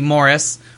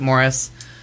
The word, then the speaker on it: Maurice: Morris, which reads like Maurice, but you pronounce it Morris, was the first happy ending gay story ever, and they...